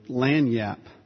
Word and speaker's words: lagniappe: L-A-G-N-I-A-P-P-E. As a word of North American origin, it's pronounced unbelievably lanyap.